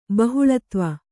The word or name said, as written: ಬಹುಲತ್ವ